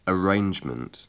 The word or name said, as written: arrangement